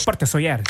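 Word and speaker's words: Sóller: Porte de Soler. Ich glaube, es spricht man anders aus. – Porte Soler.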